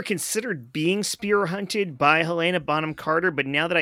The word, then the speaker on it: Helena: Guys, that's how you say the name. Listen up. It's Helena. Yeah.